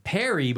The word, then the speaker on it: Perrey: Perry, but it's spelled really weird. P-E-R-R-E-Y. Perry okay.